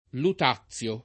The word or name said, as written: Lutazio